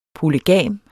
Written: polygam

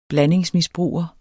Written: blandingsmisbruger

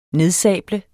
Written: nedsable

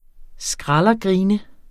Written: skraldergrine

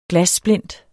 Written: glassplint

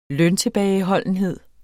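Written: løntilbageholdenhed

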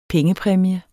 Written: pengepræmie

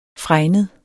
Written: fregnet

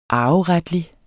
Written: arveretlig

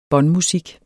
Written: båndmusik

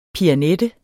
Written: PIANETTER

